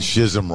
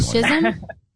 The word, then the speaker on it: schism: Oh, it just sounds so much I said shism wrong for 10 years. Okay, 20. – Schism? – Yeah, apparently it's schism.